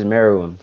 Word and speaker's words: Meruem: Oh, Netero versus Meruem from Hunter x Hunter.